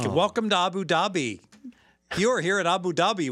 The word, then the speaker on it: Abu Dhabi: It's Abu Dhabi? – Abu Dhabi.